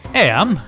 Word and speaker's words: Eyam: It's spelt E-Y-A-M, like E-M. – Eam. – But it's pronounced Eam.